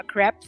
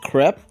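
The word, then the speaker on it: crêpe: It's pronounced crepe, not crepe. – It's not a crepe, it's a crepe. – I've always called it crepe. – And it's not a creperie, it's a creperie.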